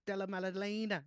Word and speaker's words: Della Maddalena: Check Della Maddalena.